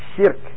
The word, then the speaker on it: شرك: But the correct one is shirk, with the sheen having a ketra. Sheen, ra, kat. The sheen has a ketra on it. The ra has a sukun, and the kaf ends with the dhammatain, insha'Allah. So it's really shirk.